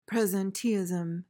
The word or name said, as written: presenteeism